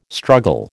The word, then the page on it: struggle: American English